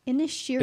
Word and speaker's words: Inisherin: Inisherin. – It's like saying Ed Sheeran, but like...